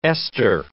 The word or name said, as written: Esther